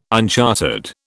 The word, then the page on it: unchartered: British English